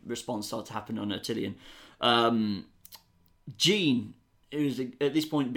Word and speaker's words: Attilan: Attilan. – Attilan. – Or Atalan, if you really want.